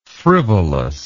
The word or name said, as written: frivolousness